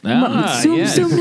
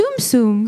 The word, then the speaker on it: Tsum Tsum: Is that how you pronounce it? – Tsum Tsum. – Tsum Tsum? It's not Tsum Tsum? – It sure isn't. I know that because I said Tsum Tsum in a video and they said, you better go re-record that.